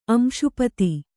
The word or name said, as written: ಅಂಶಪತಿ